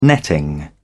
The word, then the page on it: netting: British English